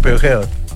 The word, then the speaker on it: Peugeot: Peugeot. – Peugeot. – Los argentinos dicen Peugeot.